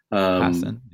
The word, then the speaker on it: Passan: Passan, yeah.